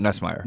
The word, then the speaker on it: Nussmeier: Now, Garrett Nussmeier, is that how you say it? – Garrett Nussmeier. – Nussmeier, thank you.